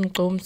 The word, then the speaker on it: gqom: So it's spelled G-Q-O-M, pronounced GQOM.